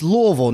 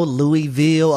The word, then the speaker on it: Louisville: And by the way, correct pronunciation on this, tell the world, it's Louisville, right? Like, I'm doing this right, Louisville. – Yeah, it's Louisville, not no Louisville or Louisville. It's Louisville.